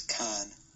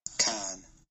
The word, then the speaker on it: Cannes: Pronunciation of this word is con. Con. – Oh. – Con. So there, it's not con. Even, there's not even an S.